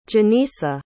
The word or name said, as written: genisa